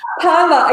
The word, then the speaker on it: PERMA: PERMA, exactly, PERMA, yeah.